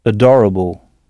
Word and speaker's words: adorable: Mais notez bien que le A initial se prononce E, adorable.